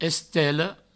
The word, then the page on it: estela: Provençal; estello